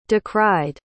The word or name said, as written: decried